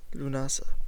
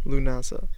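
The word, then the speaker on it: Lúnasa: Lunasa. So make sure to celebrate Lunasa. Set your calendars for it. That's spelled L-I-U with an accent. N-A-S-A. Lunasa.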